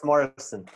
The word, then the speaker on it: Morrison: It's Morrison. – M-O-R-R-I-S-O-N. Okay, like Jim Morrison. – Just like Jim Morrison or Van Morrison.